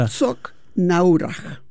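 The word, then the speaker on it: Herzogenaurach: Herzog Naurach. – Así, dije tal cual. – Naurach. Tal cual, lo dijiste fantástico.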